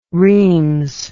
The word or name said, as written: reims